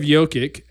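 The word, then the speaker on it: Jokić: Speaking of Jokic as the MVP potential.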